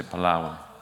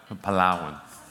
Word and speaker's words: Palawan: Everyone say Palawan. Say it like that though. Palawan.